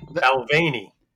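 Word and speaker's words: Balvenie: Actually, before you and I started recording, you corrected my pronunciation. I was going Balvaney. It's Balvaney.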